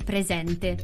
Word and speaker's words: presente: Su presente pongo l'accento grave sulla seconda E, quindi la seconda E la pronuncerò aperta, quindi presente.